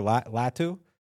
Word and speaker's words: Latu: Latu. – Latu.